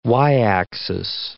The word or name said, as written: y-axis